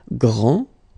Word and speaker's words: grand: This final D is not pronounced. Okay, so grand, and then the feminine form, grande. Okay, so you put this E, and then you get the sound D, D, grande.